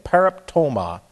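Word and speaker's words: pereptoma (παράπτωμα): This is P-A-R-A-P-T-O, long O-M-A, pereptoma.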